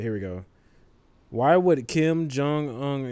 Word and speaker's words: Kim Jong Un: He made a tweet about Kim Jong Un. Am I saying his name right? – Yeah. – Okay. Yeah. – Kim Jong Un.